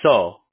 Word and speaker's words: Seo: All right, don't laugh at me here, but it's pronounced So, but it's spelled S-E-O.